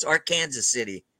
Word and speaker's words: Arkansas City: It's spelled like Arkansas City, but it's Arkansas City, Kansas.